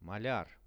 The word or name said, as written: maliar